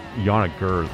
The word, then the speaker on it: Yannick Gers: Yannick Gers was just like caressing his guitar.